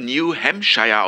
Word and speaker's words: New Hampshire: Es wird New Hampshire ausgesprochen.